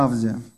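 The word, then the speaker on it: Авдий: Что мы знаем о Авдия? Вот кроме этой картинки ничего мы про не знаем. Ну, имя еще переводится как «поклоняющийся Богу» или «служащий Богу» Иегове. Авди, на А ударение для эстетствующих.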